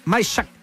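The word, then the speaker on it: Majchrzak: ¿Cómo se dice? – Kamil Majshak. – Yo dije cualquier cosa, yo lo intenté.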